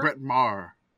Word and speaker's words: Maher: Brett Maher.